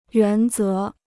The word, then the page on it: 原则: yuán zé